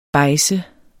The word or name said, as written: bejdse